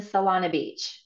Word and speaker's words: Solana Beach: Yeah, the store's in Solana Beach.